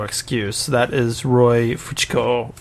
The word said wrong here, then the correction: Fokker: It's pronounced Fokker.